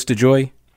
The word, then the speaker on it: DeJoy: So you mentioned this, the Postmaster General, Louis, it's DeJoy? Is that how he pronounces it, Louis DeJoy? – Yeah.